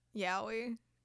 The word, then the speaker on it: Yowie: Is that how it's pronounced? Yowie? – From what I've seen, yeah.